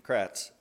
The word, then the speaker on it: Kratz: Kratz.